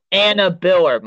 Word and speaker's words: Anna Biller: Oh, Anna Biller. My bad, Anna Biller.